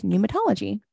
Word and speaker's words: pneumatology: Pneumatology. And that is with a silent P at the beginning for those of you that don't know.